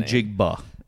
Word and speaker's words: Njigba: Behind Jackson Smith, Njigba, is how I think they always say his name. – Njigba.